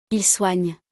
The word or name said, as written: il soigne